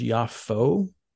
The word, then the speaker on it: Jaffa: And let me just say, too, sometimes you'll hear it pronounced Jaffa, sometimes Jaffa with a Y, sometimes Yafo with an O.